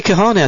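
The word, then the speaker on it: Kahne: Casey Kahane, I tell you, that's how they say it on the telly, and they must be right.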